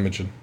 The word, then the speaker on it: Imagen: Anyway, with advanced text to image software and Google's Imagen AI, is that how you say it? – Imagen? Yep. Imagen.